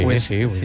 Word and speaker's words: Wesley: Pues Wesley. – Vale, vale. – ¿Que sí? – Sí, Wesley.